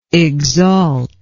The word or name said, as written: exalter